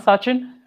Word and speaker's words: Sachin: So I'm Sachin.